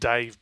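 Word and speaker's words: D4VE: There was also the first print edition came out for a comic called, well, it's pronounced Dave, but it's spelled D with the numeral 4 VE, about a robot, basically all of Earth taken over by robots.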